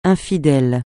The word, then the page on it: infidèle: francais